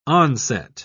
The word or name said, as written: onset